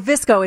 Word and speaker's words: VSCO: Admittedly, I had to Google because it's VSCO, but that's like the Webby way. You omit vowels, you know? – Yes. – So Visco is a cool photo app.